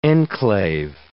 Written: enclave

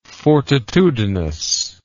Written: fortitudinous

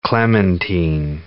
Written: clementine

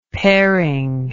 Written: pairing

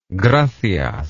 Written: gracias

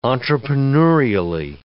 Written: entrepreneurially

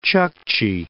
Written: Chukchi Peninsula

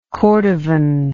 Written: cordovan

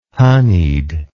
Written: honeyed

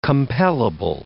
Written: compellable

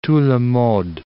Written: tout le monde

